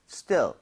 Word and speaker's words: still: 얘는 입 모양을 옆으로 이렇게 벌리는 것이 아니라 이 모양을 그대로 두고 그냥 still, still.